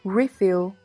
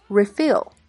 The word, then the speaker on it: refill: 做名词时，重音在前，读作 refill，比如说 coffee refill。另外，它做动词时，重音在后，读作 refill。